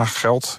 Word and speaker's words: Gelt: Spreek je dat goed uit? – Ja, Geld. Ja. G-E-L-T. – G-E-L-T, ja.